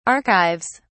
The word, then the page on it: archives: British English